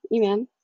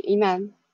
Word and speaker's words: Iman: This is me, Iman. This is how I pronounce my name, and it's an Arabic name.